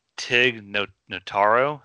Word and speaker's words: Tig Notaro: I think Mikey's a little angry about this one, but Tig Notaro, is that how you say her name? – No, it's her name, yeah.